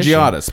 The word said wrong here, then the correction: Panagiotis: Pangiotis Mistriotis. – No, Panagiotis.